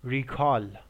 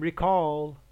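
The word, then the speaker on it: recall: مثلا توی کلمه مثل ریکال یعنی به یاد آوردن، ولی بخوام به عنوان فعل استفاده کنیم میگیم ریکال.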